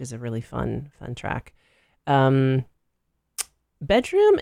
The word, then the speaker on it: bdrmm: That is a band from LA called Bedroom, but it's spelled BDRMM.